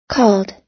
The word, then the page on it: called: American English